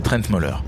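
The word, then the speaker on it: Trent Møller: C'est très simple, c'est Trent Moller.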